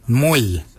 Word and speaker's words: mooly: Warrigal is spelled W-O-R-R-A-G-O-L. Warrigal. Warrigal is your upper mouth. For your nose, we say mwil. Mwil is your nose. Mwil is spelled M-O-O-L-Y.